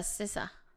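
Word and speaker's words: SZA: Ah, la Cesa.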